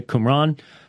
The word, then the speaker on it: Qumran: That's how it's pronounced, by the way, Qumran.